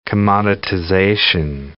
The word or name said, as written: commoditization